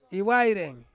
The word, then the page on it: iwaireng: Ansus